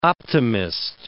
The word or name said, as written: optimist